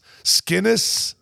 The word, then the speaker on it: Skenes: S-K-E-N-E-S. Skenes?